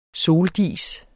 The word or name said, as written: soldis